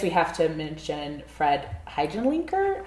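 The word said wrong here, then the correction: Fred Hechinger: Fred Heckinger. – Completely butchered that.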